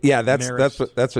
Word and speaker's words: Marist: Kelly, you got to tell me, pronounce the team that we are competing against from New York this weekend. – The Marist Red Foxes.